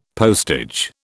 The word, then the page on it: postage: British English